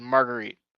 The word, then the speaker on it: Marguerite: but I think it's like Marguerite. Okay. – Yeah, I think like a margarita pizza, but ditch the Ida. It's just Marguerite.